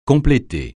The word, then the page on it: compléter: francais